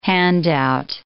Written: handout

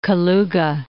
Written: Kaluga

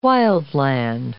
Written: wildland

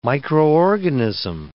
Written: microorganism

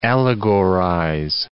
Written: allegorize

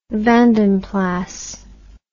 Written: vandenplas

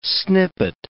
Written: snippet

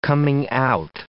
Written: coming-out

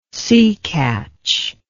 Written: seecatch

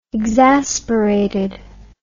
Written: exasperated

